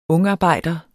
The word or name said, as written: ungarbejder